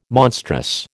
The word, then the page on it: monstrous: American English